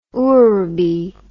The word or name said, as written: ourebi